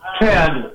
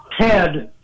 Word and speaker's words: Ted: Ted. – Chad? – Ted. – Okay, where are you calling? Can you spell that? Did you say Ted? – T-E-D, Ted.